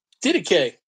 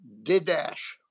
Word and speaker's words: Didache: Didache, that's correct. – D-I-D-A-C-H-E. I used to pronounce it Didash. – Yeah. – Like Jordache jeans, you remember those? – Yeah.